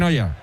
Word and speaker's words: Neuer: Sí, sí, se pronuncia en Noia.